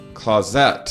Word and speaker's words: Clauset: Clauset. See, I just got to be hyper aware that I'm chock full of errors and flaws. Aaron Clauset, thank you for joining me.